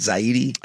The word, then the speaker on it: Zaidi: is that how you say it? Zaidi? – I believe. – I think Zaidi. So. – Yeah, Zaidi.